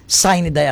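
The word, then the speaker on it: sine die: What is the right pronunciation? – Sinidai actually. Sai. – Sai. – Sinidai. – The spelling is S-I-N-E. – N-E. – Another word, die, D-I-E. It's a Latin word for no date set.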